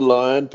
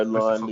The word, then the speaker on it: Lyon: It's Christopher Lyon, but you know, Lyon, Lyon, okay.